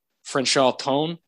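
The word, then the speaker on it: Franchot Tone: Frenchal Tone is his name. I believe that's how you say it.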